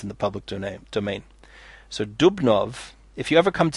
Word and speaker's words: Dubnow: There's a book called, 2 volumes, History of the Jews in Russia and Poland by Simon Dubnow, it's spelled Dubnow, D-U-B-N-O-W, but it's pronounced Dubnow.